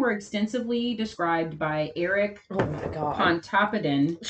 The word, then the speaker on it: Pontoppidan: Pontoppidan, that's how you say his name.